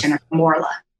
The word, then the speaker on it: Jennifer Morla: Jennifer Morla.